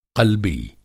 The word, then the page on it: क़ल्बी: قلبی